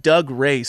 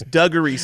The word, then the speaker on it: Dougray: But his name's not Doug Ray Scott. It's Dougree Scott.